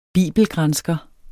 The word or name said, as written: bibelgransker